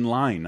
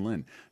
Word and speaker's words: Lyne: I learned through a friend, Dan Amrick, Adrian Lyne, not Adrian Lynn. I've been mispronouncing his name.